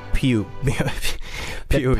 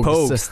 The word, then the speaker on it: Pogues: Ja, det var alltså låten Fairytale of New York tillsammans med The Pew, Pew, Pogues.